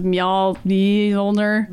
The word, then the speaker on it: Mjolnir: Yeah, they've got that extra J in there, but it's just pronounced like a Y because of Mjolnir.